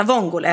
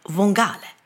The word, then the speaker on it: vongole: Så att han uttalar vongale istället. Pasta vongale.